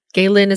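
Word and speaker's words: Geyelin: I think I'm pronouncing this correctly. It's G-E-Y-E-L-I-N. Galin is how I think it is.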